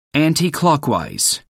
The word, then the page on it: anticlockwise: American English